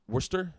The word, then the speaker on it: Worcester: Wor- Worcester. Is that how you pronounce it? Worcester, Mass. Right.